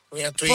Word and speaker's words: Antoine: Pure la cantava pure uno francese, Antonin. Antoine, Forse Antoine. – scusa. Che pronuncia che c'ho? Antoine, sì. – Antoine, non Antonio.